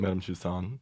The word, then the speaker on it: Tussauds: Madame Tussauds.